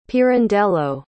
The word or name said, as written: pirandello